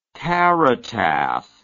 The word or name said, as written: caritative